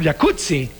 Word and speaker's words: jacuzzi: Entonces deberíamos pronunciar el jacuzzi. – Exacto. – Como pizza. Como Rampazzo, sí. Como Claudia Rampazzo.